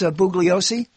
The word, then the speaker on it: Bugliosi: So the possibility as Bugliosi, did I say his name right? – Yes.